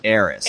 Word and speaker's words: Ayres: A-Y-R-E-S? – Yes. – I know Greg pronounces his last name Ayres. – Ayres? – Greg Ayres.